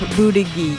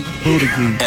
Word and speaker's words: Buttigieg: Pete Buttigieg. – Buttigieg.